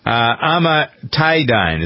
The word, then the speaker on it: amantadine: What is amantadine? A-M-A-N-T-I-D-I-N-E. Amantadine. What is it, and why is it important for you to know about this drug? Amantadine.